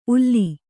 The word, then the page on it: ಉಲ್ಲಿ: ulli